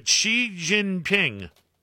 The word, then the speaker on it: Xi Jinping: Xi, is that how you say it? – Yeah, Xi Jinping.